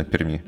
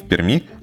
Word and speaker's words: Перми: Он сказал, что любой пермяк э, скажет, что правильно говорить не в Перми, а в Перми. То есть должен быть еще один мягкий знак, которого не существует при написании.